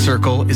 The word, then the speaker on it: CRCL: CRCL, pronounced Circle, is a free mobile crisis response service serving the Comox Valley.